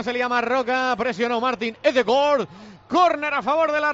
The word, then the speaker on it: Ødegaard: O cuando le coja Melchón en el Madrid, Martin Ødegaard. Es como se pronuncia en noruego.